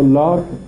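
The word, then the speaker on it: Locke: این لوک و لاک دو تلفظ از یک کلمه است. در فارسی این اوها را به صورت الف غالبا تلفظ میکنن، لوک مثلا عربا غالبا به صورت واو تلفظ میکنه. این همون جان لاکه.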